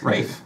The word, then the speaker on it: Ralph: See, we English speakers have the most trouble with his first name. Rolf, Ralph, Rafe, Rafe, Rafe. That's how, how we pronounce it.